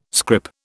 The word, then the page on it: scrip: British English